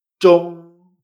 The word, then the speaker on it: Zhong: Por ejemplo, el ejemplo que tenemos aquí abajo es Z-H-O-N-G, en primer tono. Esto se pronuncia chong.